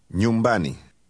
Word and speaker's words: nyumbani: Did you hear the fast NY sound at the beginning? Say, at a place. – Nyumbani.